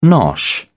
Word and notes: nosh: British English

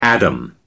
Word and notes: adam: American English

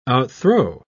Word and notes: outthrow: British English

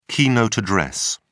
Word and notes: keynote address: British English

